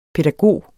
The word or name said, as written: pædagog